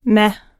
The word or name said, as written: meh